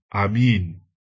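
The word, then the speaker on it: Amen: 你知道先知书是用希伯来文写的，在希伯来文相信的读音是 Amin，我们读娃的时候说 Amin，就是从希伯来文来的。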